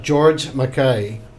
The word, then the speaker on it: George Makaye: All right, I have got George Makaye.